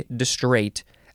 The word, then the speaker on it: distrait: There is a T sound at the end, but all that happened is that we put an E at the end of distray. Same word with an E, distrait.